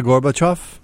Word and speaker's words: Gorbachev: How do you say it? You're the Russian scholar around here. Mikhail Gorbachev. – Thank you. – I don't do Russian. I just... – Yeah, but you threw in an extra syllable there for us, I thought.